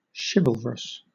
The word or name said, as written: chivalrous